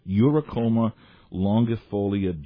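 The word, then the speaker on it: Eurycoma longifolia: Uricoma longifolia Jack. How well did I do on that pronunciation? – Very well.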